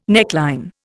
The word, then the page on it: neckline: Indian